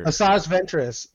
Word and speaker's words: Asajj Ventress: what's her ventress whatever her name is i forget how to say her Asajj ventress yeah Asajj so ventress.